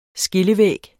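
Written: SKILLEVÆGGES

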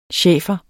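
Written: Schæfer

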